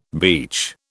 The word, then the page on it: beech: British English